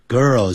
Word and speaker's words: girl: 好，我们这边没有一个 a beautiful girl，你要加 a 的话 beautiful 后面要加个 girl， girl 是 G-I-R-L 女孩子 girl，那个还是要念成 girl，因为那个 L 音呢在字尾的时候啊要念成 o girl girl girl girl girl girl。